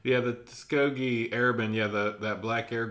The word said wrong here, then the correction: Tuskegee: It says really Tuskegee.